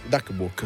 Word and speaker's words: Duck Walk: Un chitarrista di quelli che ha riportato il Duck Wall, Duck Walk, ho detto bene, però Walk, già negli anni 70 l'ha riportato in auge, un passo di Chuck Berry, che assieme a suo fratello hanno creato questo.